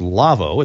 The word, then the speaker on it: Lawo: And that's our friends at Lavo, L-A-W-O, .com. It's pronounced Lavo.